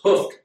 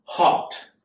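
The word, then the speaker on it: houghed: I had to look up the pronunciation of this word. I thought it was hoofed all the chariot horses, but if you listen to it, it's an old Scottish term, and it's pronounced hopped.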